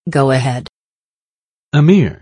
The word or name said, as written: go-ahead